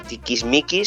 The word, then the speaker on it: tiquismiquis: Pues chicos, efectivamente hay gente muy, a ver si digo bien la palabra, tiquismiquis, sí, lo he dicho bien.